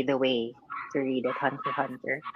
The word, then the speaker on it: Hunter x Hunter: Oh, so it's not called Hunter x Hunter. It's called Hunter Hunter. – Yeah, apparently that's how you pronounce it.